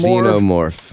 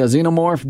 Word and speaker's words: Xenomorph: Xenomorph. – Yeah, Xenomorph.